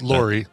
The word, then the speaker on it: Laurie: L-A-U-R-E. Okay, and it's pronounced Lori.